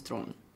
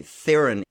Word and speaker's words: Theron: I go by Charlize Theron, but it's the real pronunciation of my last name is Thron, which is really hard for people to say. Yeah. – Okay. – Where is her name from? Is French? – South Africa. – South African. – Apparently, Theron is the preferred mispronunciation of her name.